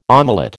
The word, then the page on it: omelette: American English